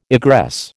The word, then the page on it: egress: American English